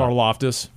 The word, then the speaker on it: Karlaftis: Karlaftis.